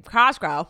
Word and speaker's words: Cosgrove: Cosgrove? – Yeah.